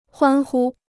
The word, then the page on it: 欢呼: huān hū